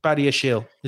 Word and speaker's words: Badiashile: I mean, in terms of Badiashile himself, very good pronunciation, by the way.